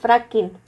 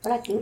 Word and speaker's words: fracking: Como el Franklin. ¿O cómo se dice? Fracking. Fracking.